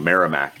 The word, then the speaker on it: Merrimack: I've told I pronounced that wrong. Merrimack.